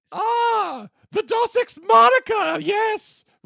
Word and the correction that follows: Deus Ex Machina: It's called Deus Ex Machina. There you Deus Ex Machina, that's it.